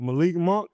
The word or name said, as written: Malik Monk